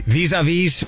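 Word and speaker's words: vis-à-vis: Tell me if I pronounce this correctly. Vis-a-vis Volume 2? – Yeah.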